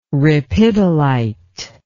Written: ripidolite

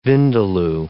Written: vindaloo

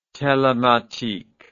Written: telematique

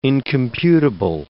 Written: incomputable

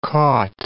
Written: caught

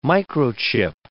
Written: microchip

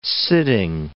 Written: sitting

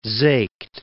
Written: Seeckt

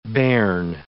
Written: bairn

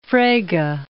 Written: Frege

